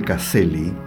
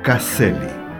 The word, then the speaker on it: Caszely: Me permito decir que Caselli se escribe C-A-S-Z-E-L-Y. Caselli. Cuenta don Carlos.